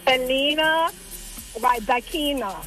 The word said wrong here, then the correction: Elena Rybakina: Elena Rybakina.